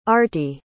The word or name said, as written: artie